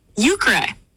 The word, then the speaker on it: euchre: Euchre, pronounced euchre, is simply the name of the card game.